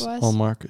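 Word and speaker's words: Linus Ullmark: Linus Olmark is how you say it.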